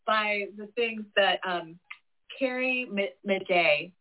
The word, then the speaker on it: Madej: Carrie Madej, and her last, it's C-A-R-R-I-E. She's a medical doctor in Georgia. Her last name is Madej, and it's spelled M-A-D-E-J.